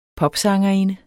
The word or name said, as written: popsangerinde